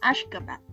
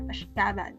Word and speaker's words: Ashgabat: Okay, I'm sorry, but Ashgabat should be pronounced as Ashgabat, not Ashgabat.